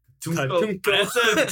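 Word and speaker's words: Katuunko: That this King Katunko. Double check the pronunciation of his name. – The Toydarian King. – He has a name. People appreciate when you call him by their name.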